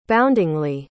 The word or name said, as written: boundingly